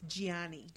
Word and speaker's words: Gianni: They keep, even the announcer lady pronounced it Johnny Versace, and I was like, it's Gianni.